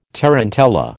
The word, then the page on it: tarantella: American English